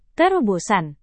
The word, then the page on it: terobosan: indonesian